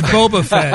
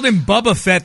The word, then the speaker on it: Boba Fett: Which I'm kind of looking forward to if they've got some kind of, wait, how do I pronounce this? Boba Fett? – It's Boba Fett. – I said Boba Fett the other day. – He called him Bubba Fett the other day, and I'm like, that's the redneck version of Boba Fett.